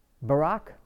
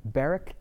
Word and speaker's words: Barak: Barak, or Barak, depending on how you want to pronounce it, timidly refused to take up arms without the support of the judge, the prophet Deborah, in Judges 4.